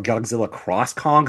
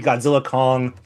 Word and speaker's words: Godzilla x Kong: I think it's supposed to be pronounced as Godzilla Kong or Godzilla Cross Kong because it uses the cross.